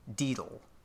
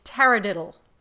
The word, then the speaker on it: taradiddle: It is T-A-R-A. – Okay, great. Okay. – Tara. Mm-hmm. And then diddle. – Diddle. Diddle, is it pronounced diddle or is it more like deedle? – Deedle. No, it's taradiddle. – Taradiddle. – D-I-D-D-L-E.